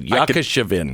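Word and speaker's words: Yashchyshyn: Apparently, a Ukrainian truck driver's daughter named Ina Yakashevin. Mm. Are you a native speaker? I okay. am. Mm-hmm. That's the way you pronounce it, Yakashevin. I could tell.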